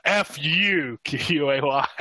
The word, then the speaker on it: Fuquay: No, that that used to be what we would yell when I was in high school was uh, F U Q U A Y at the other teams, and then we got in a lot of trouble at football games for saying fuck people.